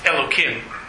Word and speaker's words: Elokim: One is that a four-letter word that starts with a yud and a hey is pronounced Elokim. That's how you pronounce it.